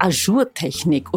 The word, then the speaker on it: Ajour-Technik: Ich würde gerne wissen, weil ich das öfter schon gelesen habe in irgendwelchen Modemagazinen, was ist bitte diese Ajour-Technik? Oder ich hoffe, ich habe es richtig ausgesprochen.